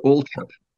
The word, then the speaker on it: Althorp: No, the H is silent, as in Thames and Thomas. So Althorp.